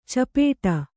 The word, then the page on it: ಚಿಪ್ಪಡು: cippaḍu; dial.